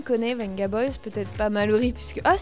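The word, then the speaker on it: Vengaboys: Ce qu'il faut savoir, c'est le V dans le nom du groupe de Benga Boys se prononce comme un B. – Benga Boys.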